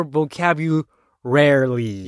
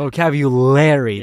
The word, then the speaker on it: vocabulary: Vocabulary or vocabularly? – Yeah. – Oh, okay. All right. – See, that's true. But you've never thought about that. – Yeah, never hesitated. – Yeah, because the right pronunciation is in your mind. – The vocabulary. Yeah. The le is L.